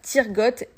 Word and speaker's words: Tyrgoth: c'est tout simple, euh, ça se prononce, voilà, Tirgot et Ixi, il n'y a pas de, de grandes difficultés, vous le prononcez à la française et tout ira bien.